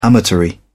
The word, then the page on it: amatory: British English